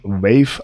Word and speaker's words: waif: Also, ich habe ja Übersetzung Wave, also W-A-I-F. Wie gesagt, eine andere Schreibweise, aber die gleiche Aussprache.